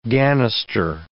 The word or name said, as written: ganister